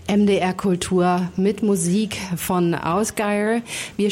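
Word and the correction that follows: Ásgeir: spielen wechselnd, ich glaube, so spricht man den. – Ich glaube, der heißt Asgeir. Ist Isländer.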